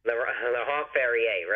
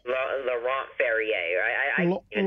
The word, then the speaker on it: Laurent Ferrier: Number 99, Laurent Ferrer. – Laurent Ferrier, right. – How would I say it? Laurent? – Laurent Ferrier.